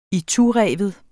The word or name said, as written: itureven